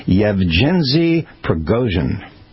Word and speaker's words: Yevgeny Prigozhin: It's Yevgeny Prigozhin.